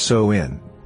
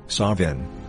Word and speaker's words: Samhain: Samhain, pronounced So-in, Savin, or Samhain, means end of summer and is the third and final harvest.